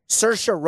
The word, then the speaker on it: Saoirse: Saoirse Ronan, Best Actress.